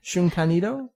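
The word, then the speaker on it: Shunkan Idō: It's actually Shun Kan Ido.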